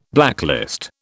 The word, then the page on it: blacklist: British English